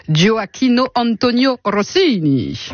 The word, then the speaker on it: Gioacchino Antonio Rossini: Gioacchino Antonio Rossini.